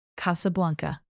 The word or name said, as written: casablanca